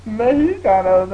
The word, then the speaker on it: Mexicano: Mexicano. The X is not pronounced he.